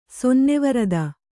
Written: ಸೊನ್ನೆವರದ

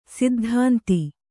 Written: ಸೈದ್ಧಾಂತಿ

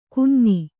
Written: ಕೊಞ್ಞೆ

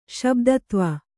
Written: ಶಬ್ದತ್ವ

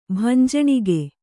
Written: ಭಂಜಣಿಗೆ

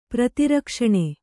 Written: ಪ್ರತಿರಕ್ಷಣ

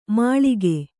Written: ಮುಳುಗು

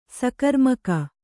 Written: ಸಕರ್ಮಕ

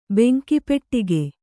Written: ಬೆಂಕಿಪೆಟ್ಟಿಗೆ

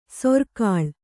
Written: ಸೂರೆಗೊಳ್

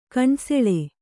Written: ಕಣ್ಸೆಳೆ

